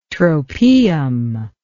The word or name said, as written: tropaeum